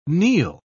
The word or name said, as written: kneel